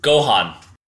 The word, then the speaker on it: Gohan: Gohan.